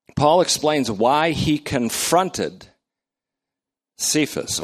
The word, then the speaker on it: Cephas: Paul almost always refers to him, except in a few occasions, as Kepha or Cephas, as we pronounce it. Cephas, which Aramaic is Kepha.